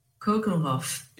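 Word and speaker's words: Keukenhof: E eu vou falar sobre ela falar a pronúncia, Juju, do, daquele que é o parque mais bonito do mundo qual é a pronúncia? – Kukenhof. – Esse mesmo.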